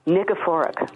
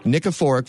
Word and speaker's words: Nikiforuk: Nikiforuk. – Nikiforuk from Calgary.